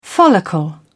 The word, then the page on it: follicle: British English